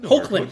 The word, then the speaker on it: Hoechlin: We're debating how to say this guy's name just before we started. – Yeah, exactly. – And I think we landed on Tyler Hoechlin. – Yeah, Hoechlin or Hoechlin? – Hoechlin, that's what we landed on.